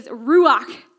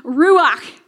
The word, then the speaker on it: ruach: In the Old Testament, the Holy Spirit, the word Holy Spirit is Ruach. Now you have to say it like you have something stuck in the back of your throat. So everyone say with me, Ruach.